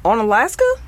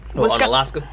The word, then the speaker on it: Onalaska: I believe it's pronounced Onalaska. – Looks like it. Onalaska.